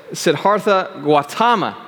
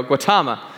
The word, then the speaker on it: Siddhartha Gautama: I always have a hard time pronouncing his name but it's Siddhartha Gautama. There we go, Gautama.